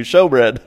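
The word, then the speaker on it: shewbread: It's pronounced what? – It's spelt shoe, S-H-E-W, right? – Yeah, S-H-E-W, showbread.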